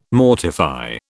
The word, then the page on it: mortify: British English